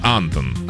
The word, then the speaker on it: Антон: Антонина Антон? На А ударение. Антон. – Антон.